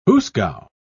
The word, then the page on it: hoosgow: British English